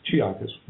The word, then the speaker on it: Chiakas: I hope I said that right, Chiakas. – Chiakas. Yes.